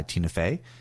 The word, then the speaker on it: Tina Fey: So we just had a little aha there from Whiskey Tango Foxtrot, produced and starred in by Tina Fey. Did I say that right? – You did.